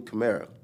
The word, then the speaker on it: Camara: But I mean, how I came about, you know, it was, you actually said it right, Tony Camara. – Uh-huh. – But nowadays, I just say camera because everybody always says camera to the point where I got tired of, like, explaining myself.